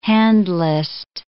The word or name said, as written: handlist